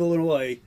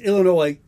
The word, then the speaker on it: Illinois: Everybody outside the state of Illinois says Illinois, S is silent. If you're in fucking Illinois, you say Illinois.